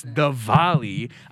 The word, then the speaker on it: Diwali: First off, it's Diwali. I mean, that's on us. I get it. It's spelled D-I-W-A-L-I. But for some reason, Indians are just goofy and it's pronounced Diwali.